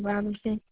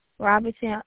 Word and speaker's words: Robinson: Ayana, A-Y-A-N-A Robinson. – Can you please spell it for me? – A-Y-A-N-A. – Mm-hmm. – Robinson, R-O-B-I-N-S-O-N.